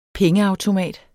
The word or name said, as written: pengeautomat